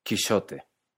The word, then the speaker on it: Quijote: En el texto original, Quijote se escribe con X, y esto es porque en esta época se pronunciaba con un sonido de sh, que mucho tiempo después se convirtió en la J que conocemos, pero en esta época la X se pronuncia firmemente como sh.